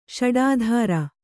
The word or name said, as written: ಷಡಾಧಾರ